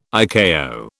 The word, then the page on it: ICAO: British English